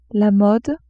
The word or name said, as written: mode